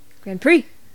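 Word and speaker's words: GrandPré: Mary Grand Prix. – All right. That's a cool name. – Her last name is GRANDPRE with a little... – Grand Prix!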